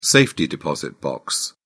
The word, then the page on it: safety deposit box: British English